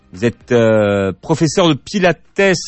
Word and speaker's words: Pilates: C'est Pilates, Joseph Pilates avait des origines grecques, donc et ça se prononce, et il était allemand surtout, donc on prononce toutes les lettres en allemand. Et donc je pense que lui-même disait Pilates. Et puis la méthode s'est développée aux États-Unis. Donc aux États-Unis aussi, on prononce toutes les lettres. Mais c'est en France qu'on a un peu enlevé le S. Donc on peut dire les deux.